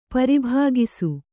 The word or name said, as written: ಪರಿಭಾಗಿಸು